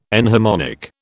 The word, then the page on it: enharmonic: British English